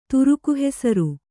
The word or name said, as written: ತುರುಕುಹೆಸರು